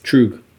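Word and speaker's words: Tröegs: How do you pronounce this again? Tregs. – Troog. – Troog. – How do you spell it? – Local-ish. – A T-R-O-E-G? I think it's Troog.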